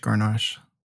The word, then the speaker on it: Grenache: So what is what is French Grenache? – Am I saying that right?